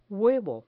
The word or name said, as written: huevo